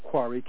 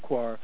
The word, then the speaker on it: Koire: You can read Rosa Quare's, some people call pronounce it Quare, K-O-I-R-E, Rosa Quare, Behind the Green Mask.